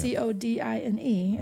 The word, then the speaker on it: codine: C O D I N E, and they pronounce it codine.